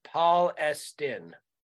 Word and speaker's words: Palestine: But residents pronounce it Palestine.